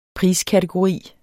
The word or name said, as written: PRISKATEGORI